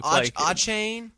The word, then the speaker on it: Achane: So is Brandon Ayuk. – Achane. I always say it wrong.